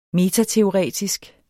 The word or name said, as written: metateoretisk